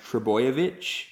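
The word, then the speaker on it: Trbojevic: Yeah, he's off contract at the end of the season, and They've got this young kid coming through, and I think his surname is pronounced Trebojevic. – Yeah.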